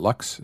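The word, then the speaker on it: Lucques: Oh, is it Lux? Is that how you pronounce it? – Yeah.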